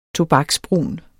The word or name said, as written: tobaksbrun